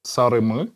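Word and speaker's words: sarımı: Э, видишь, здесь э, есть две буквы И э, в турецком. Одна с точечкой, а другая без точечки. Вот, которые без точечки, это эквивалент нашей буквы «ы». – Угу. – Так что здесь произношение «сарымы». Йо, эфендим, кырмызы, ве сары де иль, иште орафта буюк, ве гузель элмадар ва бунар насыл.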